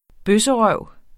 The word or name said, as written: bøsserøv